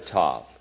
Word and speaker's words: Tav: Well, scholars say that the word, translated mark, is just the last letter of the Hebrew alphabet, pronounced Tav or Ta.